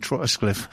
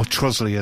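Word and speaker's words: Trottiscliffe: I was just going to say, for God's sake, nobody mentioned Trotscliffe. Or Trosley, as it's pronounced.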